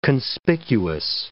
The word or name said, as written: conspicuous